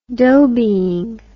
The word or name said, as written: dhobeying